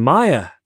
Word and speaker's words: Maya: Love from Maya to rhyme with fire.